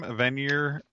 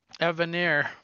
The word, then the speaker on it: Evanier: the final issue, at least, the Scooby-Doo number 9 for Marvel is written by Mark, how do you say his last name? Avenir? – Evanier.